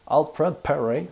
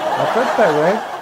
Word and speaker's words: Alfred Perry: Alfred Perry, pour ceux qui ne savent pas c'est qui, Alfred, ben avec, la, prononciation anglaise, ça serait peut-être mieux. Alfred Perry. Alfred Perry.